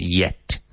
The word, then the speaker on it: yet: I'd be careful of your pronunciation of yet. We want to avoid that jet sound. The goods haven't been shipped yet.